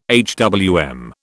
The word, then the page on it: HWM: British English